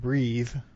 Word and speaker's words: breathe: Actually, breath and breathe, the vowel sound is different, but it could be related to the word wreath and wreath.